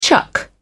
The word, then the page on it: chuck: British English